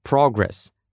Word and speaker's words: progress: Согласитесь, всегда хочется видеть свои успехи быстрее, и чтобы так было, запоминайте слово «progress». Если ударение на первом слоге, то это существительное «прогресс», «успехи», «продвижение», «развитие».